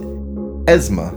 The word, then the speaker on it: Esme: A big thanks to our quiz daddies, Brandon Long, Adam Volk at Esme, and I'm sorry, now I can start pronouncing it correctly because he pronounces it Esma. Okay. As in short for Esmeralda.